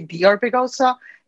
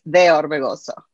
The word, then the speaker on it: De Orbegosa: So if you want to say it in English, it will be De Orbegosa. In Spanish, is De Orbegosa.